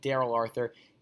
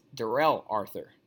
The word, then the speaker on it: Darrell Arthur: A quick correction: Josh rightly pointed out that I said Daryl Arthur. It is Darrell Arthur, and we'll see if he signs with a team after I pronounce his name wrong.